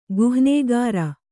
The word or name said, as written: ಗುಹ್ನೇಗಾರ